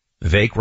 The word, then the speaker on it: Vivek: Vivek rhymes with cake, for anyone who's wondering.